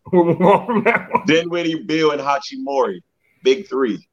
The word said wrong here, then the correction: Hachimura: Man, pronounce my man's name right. It's Hachimura, okay?